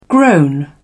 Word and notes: groan: British English

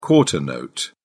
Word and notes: quarter note: British English

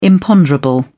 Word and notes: imponderable: British English

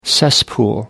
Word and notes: cesspool: British English

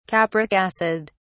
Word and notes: capric acid: British English

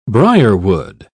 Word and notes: briarwood: British English

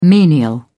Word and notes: menial: British English

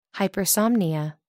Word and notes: hypersomnia: American English

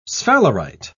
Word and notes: sphalerite: British English

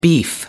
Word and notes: beef: American English

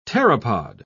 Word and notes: pteropod: British English

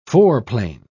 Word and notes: foreplane: British English

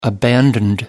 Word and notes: abandoned: British English

